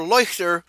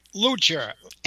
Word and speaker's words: Leuchter: L-E-U-C-H-T-E-R, Leuchter. But I don't know how you pronounce that in English, Fred. – Leuchter. – Leuchter. – L-O-O-T-C-H-E-R. The T is silent.